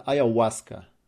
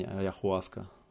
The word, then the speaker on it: Аяуаска: Буква H, Очо, или как она там, Ачо. Она не читается в испанском языке, ни на каком языке. Называется Аяуаска. А не Аяхуаска.